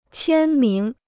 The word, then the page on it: 签名: qiān míng